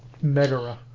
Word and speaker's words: Megara: Megara, as they pronounce it.